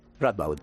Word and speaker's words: Radboud: Radboud. – Radboud, eso, ya lo he dicho regular.